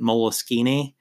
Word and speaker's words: Moleskine: It's spelled Moleskine, but they pronounce it Moleskine.